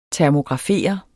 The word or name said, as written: termografere